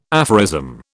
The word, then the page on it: aphorism: British English